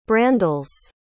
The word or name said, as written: brandeles